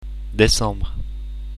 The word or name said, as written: decembre